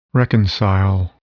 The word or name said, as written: reconcile